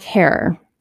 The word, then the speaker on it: Herer: Jack Herer, pronounced like terror.